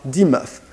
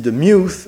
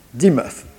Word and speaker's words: Demuth: Ce peintre est Charles Dimuth. On prononce aussi de Muth, mais il préférait que l'on dise Dimuth. D-E-M-U-T-H. Charles Dimuth.